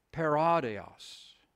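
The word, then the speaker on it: paradios (παράδεισος): The Greek word is paradios. So it's spelled P-A-R-A-D-E-I-S-O-S. Very similar to our word, paradise.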